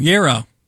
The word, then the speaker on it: gyro: G-Y-R-O. It's not gyro. – Gyro. – No, it's gyro. – Oh, gyro. – It's Y-E-E-R-O. Gyro. – Close. – Okay, this one. Acai. A-C-A-I.